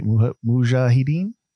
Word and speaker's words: Mujahideen: I think I'm saying that right? Mujahideen? Yeah, that's right.